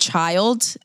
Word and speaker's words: Chiiild: It's this band named Child. It's spelled C-H-I-I-I-L-D.